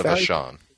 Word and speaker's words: Vachon: So, you know, when she was there, she feuded with Luna, I forget how to pronounce her name, Vachon? – Luna Vachon. – Vachon, there you go.